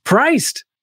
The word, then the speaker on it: PRYCD: Priced. I love Priced.